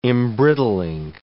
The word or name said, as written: embrittling